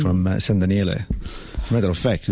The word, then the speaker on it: San Daniele: San Daniele. – San Daniele.